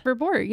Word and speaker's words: Verboort: Verbort, yeah.